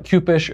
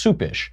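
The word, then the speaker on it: Cupich: But Cupish, I think it's actually pronounced Soupish, but people call him Cupcake, so then they think it's Cupish. But I believe it's Soupish, whatever his name is.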